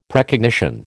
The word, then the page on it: Precognition: American English